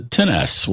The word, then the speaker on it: XS: And if you do know how to pronounce it, it's the 10S, which sounds a lot like tennis.